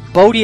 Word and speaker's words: Bodhi: Bodie is spelled B-O-D-H-I.